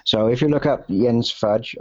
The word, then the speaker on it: Jens: And I pronounce the name Jens in Danish because it's a Danish name. I am Danish. A lot of people, English-speaking people, will say Jens because it's J-E-N-S.